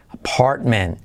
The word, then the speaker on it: apartment: Apartment. That's not an apartment. Y la T de apartment casi, aunque yo la pongo ahí, pero casi no se dice.